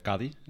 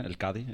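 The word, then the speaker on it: Cádiz: Het zal niet Castellaans zijn, maar Andalusisch. Hè? Dus Cadi, hè? El Cadi.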